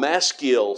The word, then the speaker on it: Maskil: They're called Maskil Psalms, and it's some spell it M-A-S-K-I-L, and sometimes you'll see it spelled, and if you have a Bible that has study notes of any kind, you'll probably see that on some of these. Sometimes it's spelled M-A-S-C-H-I-L, but it's pronounced the same way.